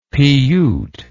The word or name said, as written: piyyut